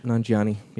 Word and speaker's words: Nanjiani: Kumail Nanjiani? – Nanjiani, yeah.